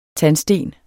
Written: tandsten

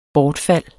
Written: bortfald

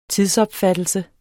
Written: tidsopfattelse

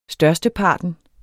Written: størsteparten

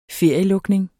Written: ferielukning

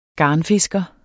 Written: garnfisker

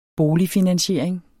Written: boligfinansiering

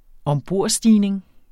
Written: ombordstigning

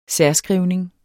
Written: særskrivning

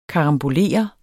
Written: KARAMBOLERER

